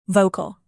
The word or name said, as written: vocal